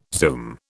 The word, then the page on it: zoom: British English